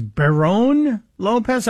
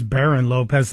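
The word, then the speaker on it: Barrón-López: Lauren, did he say her name is Barone Lopez? I thought it's Baron Lopez. That's how it's spelled.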